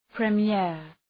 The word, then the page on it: premier: prı’mıər, ‘pri:mıər